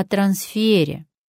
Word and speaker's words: трансфер: Поэтому запоминаем правильное ударение в слове «трансфер». Трансфер стоит дорого.